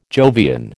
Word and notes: Jovian: American English

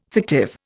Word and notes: fictive: Indian

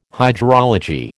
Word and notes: hydrology: American English

